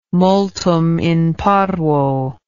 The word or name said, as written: multum in parvo